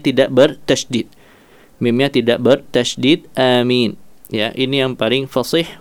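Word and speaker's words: آمين: Qala al-ulama'u afsahuha amin bil maddi wat takhfifil mim. ya, ya, amin yang paling fasih adalah dengan amin dengan mad, ya, dengan mad pada alif maupun mim,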